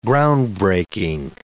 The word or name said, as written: groundbreaking